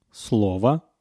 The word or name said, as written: slovo